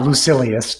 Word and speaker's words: Lucilius: Yeah, you know, I think as long as people understand it, then you can pronounce it any way you like. I usually say Lucilius, but yeah.